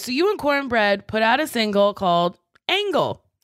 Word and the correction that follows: Angle: It's pronounced Angel, but it's spelled Angle.